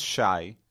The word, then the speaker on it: Shai: So my name is Shai. In Hebrew, it's spelled Shin Yud, which is basically the first, like SH, and then AY.